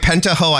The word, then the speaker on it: Pentaho: Is that how you say it? – You know, it's funny. We said Pentaho, okay. But Pentaho, I think, is how a large majority of folks say it.